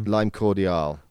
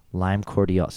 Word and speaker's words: Lime Cordiale: which is you're gonna have to pronounce her last name for me. Lime. Lime Cordiale. Lime Cordiale.